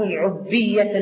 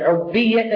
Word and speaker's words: عبية: عبية الجاهلية، هكذا نطقها صحيح، عبية الجاهلية، العبية هو الكبر،